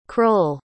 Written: crull

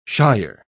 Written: shier

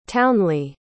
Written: townley

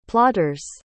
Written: plotter's

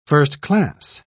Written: first-class

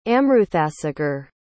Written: amruthasagar

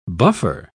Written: buffer

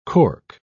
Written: cork

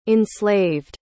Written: enslaved

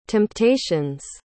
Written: temptations

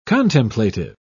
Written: contemplative

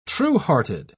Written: truehearted

